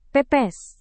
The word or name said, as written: pepes